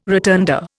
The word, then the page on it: rotunda: Indian